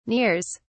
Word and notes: nears: British English

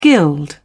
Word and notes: gild: British English

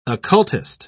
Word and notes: occultist: British English

